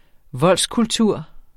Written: voldskultur

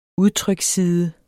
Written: udtryksside

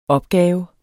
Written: opgave

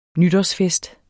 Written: nytårsfest

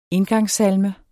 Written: indgangssalme

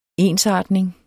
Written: ensartning

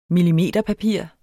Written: millimeterpapir

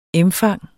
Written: emfang

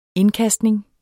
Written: indkastning

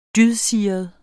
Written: dydsiret

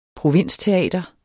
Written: provinsteater

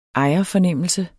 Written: ejerfornemmelse

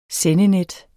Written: sendenet

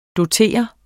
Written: DOTERET

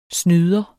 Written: snyder

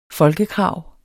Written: folkekrav

